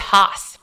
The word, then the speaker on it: Haas: It's Haas. – Haas. – Like the avocado.